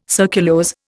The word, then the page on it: surculose: Indian